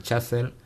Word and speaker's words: Chazelle: de Damien Chazelle, La La Land.